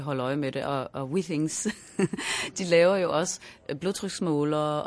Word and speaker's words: Why Things: Nu nævner du Why Things, som jeg hører dem udtale som We Things. – Ja, det er fordi, det er fransk. – Fordi det er jo et fransk firma, så det er faktisk We Things.